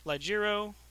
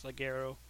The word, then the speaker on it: Ligero: These guys had an event scheduled that was going to include NXT UK stars Joseph Connors, Ligero, I know I just botched that pronunciation, I think it's Ligero,